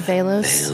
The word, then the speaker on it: Velos: Zephyr Velos. – Velos, how do you spell that? – The last name? – Yeah. – V-E-L-O-S. – And the first name? – Z-E-P-H-E-R.